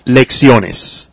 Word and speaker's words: lecciones: If I had said lecciones, if that were the proper way to pronounce lecciones, then I would put the accent on the E, but it's not. So in this case, when a word ends in S and the stress is on the next to the last syllable, there's no accent. So it's lecciones.